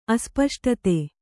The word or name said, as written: ಅಸ್ಪಷ್ಟತೆ